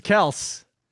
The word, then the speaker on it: Kelce: Well, it was like Kelce came out and said that's not how he pronounces his name. No one listens. It's Kels.